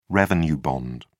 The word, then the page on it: revenue bond: British English